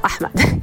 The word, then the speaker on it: Ahmed: Ahmed. That's how you pronounce it.